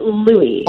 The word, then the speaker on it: Louis: Well, first of all, they pronounce it Louis. – Oh, Louis, like Louis the 15th. Okay.